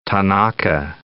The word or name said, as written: Tanaka